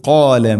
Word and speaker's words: قال: وإذ قال موسى وإذ ذلك ذا ذي ذو أذ وإذ قال وإذ قال എന്നല്ല പറയേണ്ടത്. وإذ قال قاف സ്വരഭാരത്തിൽ ഉച്ചരിക്കേണ്ട അക്ഷരം قال قال എന്ന് പറയരുത്, قال അല്ല قال موسى لقومه ഇവിടെയും قافിന്റെ ഉച്ചാരണം ശ്രദ്ധിക്കുക.